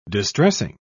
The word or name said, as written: distressing